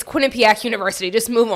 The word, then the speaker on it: Quinnipiac: it's Quinnipiac. You should you should pronounce that. I'm a thousand percent sure it's Quinnipiac.